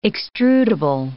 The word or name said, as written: extrudable